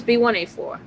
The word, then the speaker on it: B1A4: But it's B1A4. That's how you say it.